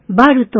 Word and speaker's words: バル: バルとは、 BAR のスペイン語の読み方で、本場スペインではお気に入りの一品を求めて、一晩に何軒もはしごする人が多いそうです。